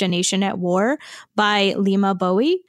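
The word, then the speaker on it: Leymah Gbowee: Which I watched like 4 videos to figure out how to pronounce it correctly. So it is spelled LEYMAH and then GBOWEE. So I hope that Lima Bowie is correct. That is how Stephen Colbert said it.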